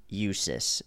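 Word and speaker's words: USUHS: That is USIS is how it's pronounced.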